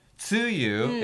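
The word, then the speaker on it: 梅雨: Tsuyu is how you say rainy season. – So, tsuyu じゃなくて tsuyu ne. – T-S-U-Y-U. – T-S-U-Y-U.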